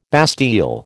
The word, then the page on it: bastille: American English